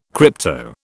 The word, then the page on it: crypto: British English